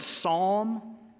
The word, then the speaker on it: psalm: And oh, by the way, the word psalm or psalmist, the P is silent, not the S, okay?